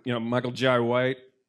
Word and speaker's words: Michael Jai White: I can't think of it off the top of my head, but fucking, Michael Jai White.